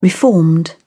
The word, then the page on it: reformed: British English